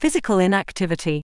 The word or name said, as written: physical inactivity